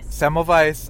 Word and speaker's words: Semmelweis: Semmelweis.